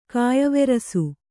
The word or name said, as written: ಕೆಯ್ವೆರಸು